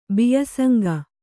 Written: ಬಿಯಸಂಗ

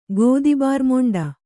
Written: ಗೋದಿಬಾರ್ಮೊಂಡ

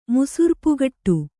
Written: ಮುಸುರ್ಪುಗಟ್ಟು

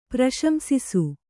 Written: ಪ್ರಶಂಸಿಸು

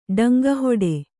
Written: ಡಂಗಹೊಡೆ

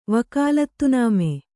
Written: ವಕಾಲತ್‍ನಾಮೆ

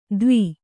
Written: ತವಿ

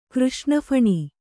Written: ಕೃಷ್ಣಫಣಿ

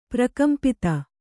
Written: ಪ್ರಕಂಪಿತ

